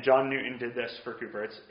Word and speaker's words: Cowper: At the end of this series on friendship, I'm going to take a class just to look at the friendship of William Cooper and John Newton. I know I've mentioned them a bunch of times. Cooper, it's spelled Cowper if you go to look him up.